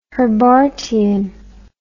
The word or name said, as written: Herbartian